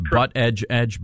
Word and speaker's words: Buttigieg: James, it's butt edge edge, butt edge edge. We want to pronounce the man's name correctly.